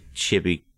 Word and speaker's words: chibi: So somebody posted some fan art the day we did our docs episode, and it was a little like chibi Mr. Freeze and a little chibi Killer Croc. – Chibi, I believe it's pronounced.